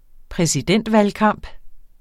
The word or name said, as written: præsidentvalgkamp